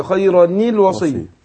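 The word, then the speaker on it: خيراً الوصية: فاتخلص من التقاء الساكنين بايه بكسر الايه الاول. نعم. نون التنوين او الخيرني الوصيه خيرني الوصيه وصية.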